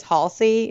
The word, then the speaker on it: Halsey: I think it's Halsey is how you say her name.